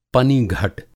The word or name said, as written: पनिघट